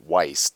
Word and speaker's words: Weiss: One member of Weiss's firm, that's a German term for white, and it's a common Jew last name, Weiss, W-E-I-S-S. E-I is pronounced I in German, opposite of what it would be pronounced in English.